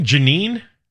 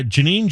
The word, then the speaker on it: Jeanine: And the guy's name, are we still calling him Janine? Or is there some weird pronunciation for that? – Yes, that is still what we're calling him. – J-E-A-N-I-N-E.